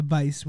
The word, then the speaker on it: Weisz: Christian predicted Rachel Weisz. Is that how you pronounce her name? – Yeah, Weisz with a V kind of.